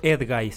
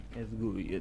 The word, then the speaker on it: Edguy: Sí, hay una canción que se llama Superhéroes del grupo Edguy. – Se escribe Ed G-U-I. Edguy, Edguy.